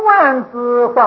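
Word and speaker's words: 卍字: 它不是一个字，它也没有，也没有是一什么音，但是这个字的意，这个相的相好的意思，它是代表的吉祥，万德万能，吉祥如意是代表这个意思，所以武则天就把它念作万字，那么以后大家也就跟着念万字，这是三十二相之一。那么从胸万字放光啊，也就是代表心性的全体作用，所以是万德万能，表这个意思。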